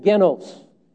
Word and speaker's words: genos: The word here, G-E-N-O-S, genos, is how we pronounce the Greek word.